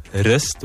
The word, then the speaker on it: wrist: The problem is we're saying wrist with the W. The silent W.